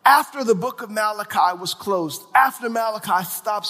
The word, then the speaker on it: Malachi: Now, the Old Testament ends in the book called Malachi.